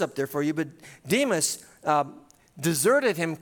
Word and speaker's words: Demas: He said, "For Demas," it's actually pronounced Damas in Greek, but "for Demas, in love with his present world, has deserted me and gone to Thessalonica.